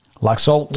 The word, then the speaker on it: Laxalt: How do you say his name again? – Laxalt.